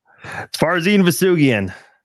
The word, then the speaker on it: Farzin Vousoughian: Farzin, soft G, hard G, Vousoughian. How we doing this? – Farzin, Vousoughian. – Nice. – Farzin, Vousoughian. All right. Hard G and Farzin.